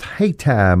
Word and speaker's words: Hayti: It's in Hayti, pronounced Hayti, Missouri.